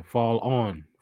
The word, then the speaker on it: Fallon: Yeah, Fall On. Fall On. You gotta pronounce it right.